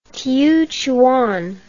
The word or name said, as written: chiuchuan